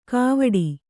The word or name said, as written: ಗಾವಠಿ